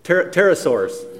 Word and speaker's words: pterosaur: If I say this wrong, get me what it's Petrosaur? Did I get that right? Petrosaur? Pterosaurs. The P is silent, okay, as I should be most days.